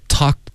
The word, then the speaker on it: talk: Okay, talk to 要注意，不是偷。 Okay, 它是 ta 这个嘴巴张开，偷他跟 talk to 区别很大。